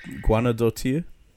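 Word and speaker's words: Guðnadóttir: Now, I'm going to struggle with this last name, but Hilda Guendottier. I don't know how to say her last name. Anyway, she won it for Joker. – Guendottier? – Yeah, there we go.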